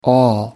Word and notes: all: American English

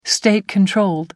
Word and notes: state-controlled: American English

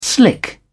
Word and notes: slick: British English

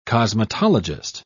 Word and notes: cosmetologist: British English

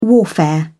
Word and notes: warfare: British English

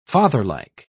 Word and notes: fatherlike: British English

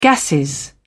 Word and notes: gasses: British English

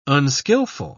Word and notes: unskilful: British English

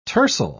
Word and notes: tercel: British English

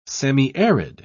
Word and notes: semiarid: British English

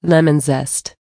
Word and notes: lemon zest: American English

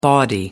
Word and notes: bawdy: American English

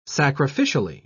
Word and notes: sacrificially: British English

